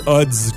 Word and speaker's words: Adz: Ils disent Odds comme dans ODDS, comme si okay. C'était ODDS. – OK. – The Age of Odds. Pourquoi? Je sais pas. – Moi non plus, je sais pas. – Mais ça s'écrit ADZ, c'est ça? – Alors, tu connais Sufjan Stevens?